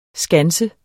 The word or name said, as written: skanse